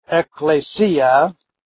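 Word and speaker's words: ekklesia (ἐκκλησία): By the way, the Greek pronunciation is ekklesia. The Latin pronunciation is ecclesia with the double C instead of the double K.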